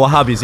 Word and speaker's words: Wahhabis: Wahhabis? – No, Wahhabis. He said it right the first time.